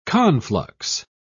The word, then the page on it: conflux: British English